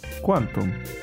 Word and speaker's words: Qwantum: Y Quantum. Quantum. Quantum. No, qua, no, es que es Q-W-A-N-T-U-M. – Quantum.